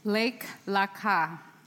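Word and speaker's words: Lake Laka (לך לך): So the Hebrew word in the original translation, go, also das hebräische Wort in der originalen Übersetzung geh, is pronounced wird so ausgesprochen. Lake Laka. Lake Laka.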